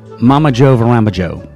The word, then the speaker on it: Mamajoevramajoe: To attempt to pronounce this band name that you just heard doing I'm a Christmas Potato. Mama Joe Varama Joe. It's all one word. In fact, I'm going to spell it. M-A-M-A-J-O-E-V-R-A-M-A-J-O-E.